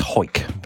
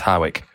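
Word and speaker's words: Hawick: You're gonna have to forgive my pronunciation here, but apparently it's Hoik, but I look at it and go, it's Howick to Duns.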